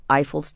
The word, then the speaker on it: Eiffel: And you pronounced his name Eiffel? – Eiffel. Well, that's a French. I, that's we would French. say Eiffel. Yes. Eiffel. Okay, so if you're talking to a French person, Eiffel.